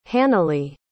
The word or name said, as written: hannele